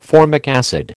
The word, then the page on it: formic acid: American English